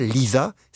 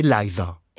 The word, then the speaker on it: Liza: Une chanson pour qu'enfin on prononce correctement son prénom. C'est pas Liza, c'est Liza.